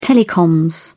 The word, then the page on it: telecoms: British English